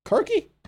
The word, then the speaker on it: Kirke: K-I-R-K-E. – K-I-R. – R. – K-E. K-E. Kirky? – Yeah, it's pronounced Kirke.